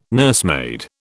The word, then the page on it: nursemaid: British English